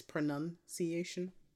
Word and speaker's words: pronunciation: It's not spelled like, it's just spelled with a U, not like a O-U. It's pronunciation.